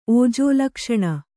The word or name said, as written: ಓಜೋಲಕ್ಷಣ